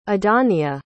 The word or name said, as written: idania